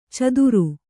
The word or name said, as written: ಚದುರು